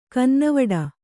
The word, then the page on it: ಕನ್ನವಿಡು: kannada